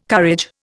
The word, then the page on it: courage: Indian